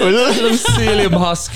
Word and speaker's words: psyllium: A psyllium. It's like aluminium. It's like pterodactyl. – It's like aluminium. – It's got a silent P. It's so psyllium.